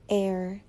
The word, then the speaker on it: heir: So these words begin with the letter H, but the H is silent. So the word is actually pronounced with the vowel sound, hour, air.